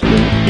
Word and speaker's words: Linux: Hallo, this is Linus Torvalds and I pronounce Linux.